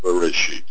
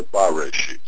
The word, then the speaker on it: Bereshit: But that word can be pronounced Bereshit or Bareshit or Boreshit. And depending on those vowels, the meaning of the word is changed. Now we are taught that the word is to be pronounced Bereshit, which means in a beginning.